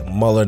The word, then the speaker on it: Muller: Alijah Muller, spelled with an A, not with an E, so A L I J A H Muller, not Miller, M U L L E R.